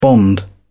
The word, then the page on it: bond: British English